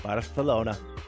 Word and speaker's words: Barcelona: And of course, the C's are pronounced like TH's. Barcelona.